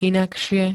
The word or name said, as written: inakšie